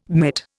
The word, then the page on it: mitt: Indian